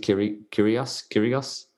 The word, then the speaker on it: Kyrgios: Because there's a chap this year, he's been around for a while, Nick, I think you pronounce it Kyrgios? – Yeah, I think so.